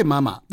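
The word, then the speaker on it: 媽媽: ねえ、このママ美食というお店なんですけど、ママという、ママという字はですね、はい、女偏に馬と書いて、マと読むんですね。まあ、日本ではあまり使わない字ですけどもね。 – そうですね。 – これが 2 つあって、ママ。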